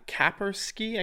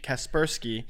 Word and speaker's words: Kaspersky: It it's uh, Ka- Kapersky. I can't remember how to pronounce Kaspersky. it. – Kaspersky.